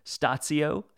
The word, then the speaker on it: statio: and forgive my pronunciation here, statio? – Oh, you're good. It is. That's a Latin term.